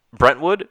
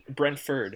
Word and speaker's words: Brentford: Was it Brentwood? Am I saying it right? – Brentford.